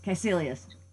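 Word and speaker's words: Kaecilius: And it's hard to pronounce, Caecilius. – That's it, Caecilius.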